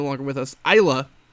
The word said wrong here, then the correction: Elia: I think I'm pronouncing it right, gets the win. Or Elia, excuse me, Elia. Elia gets the win.